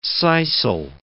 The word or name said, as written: sisal